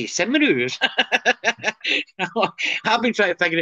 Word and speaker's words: Cymru: Cymru. – How do you say it? – Cymru.